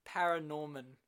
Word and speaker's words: ParaNorman: So Leica is an American stop motion animation studio, predominantly known for their feature film works such as Coraline, Paranorma, and Kubo and the Two Strings. – Say it again. – Paranorman. – It's Paranorman, yes.